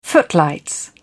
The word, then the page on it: footlights: British English